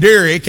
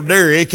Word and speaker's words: Derek: And my name, Derek, there's about 50 ways you can spell Derek, and we took the easiest way, D-E-R-E-K.